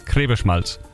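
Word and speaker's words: Griebenschmalz: Grieberschmalz. – Oh, das hast du gut ausgesprochen, jawohl.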